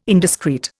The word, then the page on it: indiscreet: Indian